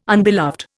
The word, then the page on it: unbeloved: Indian